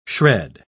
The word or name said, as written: shred